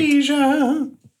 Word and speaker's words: Aja: You can hear it's not quite as, like, processed as Aja was. And it was a little bit more, it sounded like. – Aja. – Guys, come on. It, as Asia was, and I pronounced that again perfectly, okay?